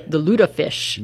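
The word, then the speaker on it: lutefisk: The lutefish.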